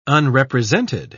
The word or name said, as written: unrepresented